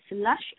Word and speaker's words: Lush: Okay, my name is Lucy Lush. It's spelled as L-U-C-Y, and my last name is Lush, L-U-S-H.